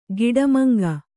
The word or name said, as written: ಗಿಡಮಂಗ